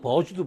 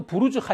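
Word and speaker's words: Burj: 아, 이거를 버즈로 읽으면 안 되는구나. 부르즈로 읽어야 되는구나. 라고 해서, 칼리파는 또 부르즈 칼리파라고 읽었어요.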